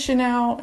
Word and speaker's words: Chenelle: My name is Chanel, and it's spelled C-H-E-N-E-L-L-E, not C-H-A-N-E-L.